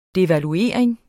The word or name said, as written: DEVALUERINGEN